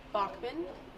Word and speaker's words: Bachman: Okay, so my name is Kara Bachman. It's K-A-R-A. B-A-C-H-M-A-N.